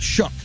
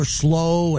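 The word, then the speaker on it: Shough: His name isn't spelled anything like Shook, but that's his name. Everyone was saying Tyler Slow and Tyler Slow. They couldn't even say his name.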